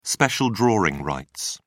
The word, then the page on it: special drawing rights: British English